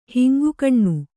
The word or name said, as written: ಹಿಂಗುಗಣ್ಣು